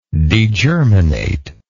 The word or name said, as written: degerminate